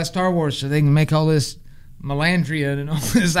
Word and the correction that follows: Mandalorian: Is it Melandrian? I don't know. I see it written. I don't know how to say it. – Are you talking Mandalorian? – There you go.